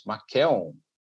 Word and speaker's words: McKeown: McKeown.